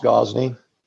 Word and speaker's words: Gozney: and Gozeni is that how pronounced that right? It's pronounced Gozney.